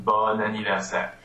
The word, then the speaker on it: bon anniversaire: It's anniversaire is a masculine word, so it's B-O-N. – Mm-hmm. – But it's not pronounced bon anniversaire or bon anniversaire. It's pronounced bon anniversaire.